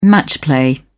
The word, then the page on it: match play: British English